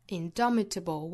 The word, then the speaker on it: indomitable: 发 capacity does not come from a physical capacity 后面 it comes from an indomitable will it 尾部的 t 做省读 from an 连读 from an indomitable will indomitable 重读在第二个音节上或者我们也按照原声重读的位置放到第一个音节上也是可以的 indomitable will indomitable